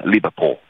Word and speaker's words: Liverpool: pur essendo di Liverpool, anzi, prova tento una pronuncia inglese, Liverpool. Va meglio, no? – Va bene, sì.